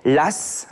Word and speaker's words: Law: L'AS, alors ça s'écrit L-A-W, mais on le, on le dit bien de cette façon, l'AS, et c'est en fait la première monnaie qui a arrive en France.